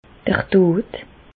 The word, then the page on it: d'r Dod: Bas-Rhin; Reichshoffen